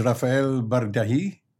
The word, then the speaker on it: Bardaji: And our special guest today is Rafael Bardaji. Am I pronouncing it correctly? – Correctly, very well.